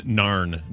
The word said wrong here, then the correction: Nairn: It's actually Nairn, I guess is how you pronounce it.